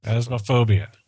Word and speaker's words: Phasmophobia: And so it was suggested that we picked up a game called Phasmophobia. And it your ghost hunters. – That? – P-H-A-S-Z-A-S. That's as is not a word. – Phasmagoria. – Phasmagoria. – Phasmagoria. Phasmophobia. Phasmophobia, right? – Phasmophobia.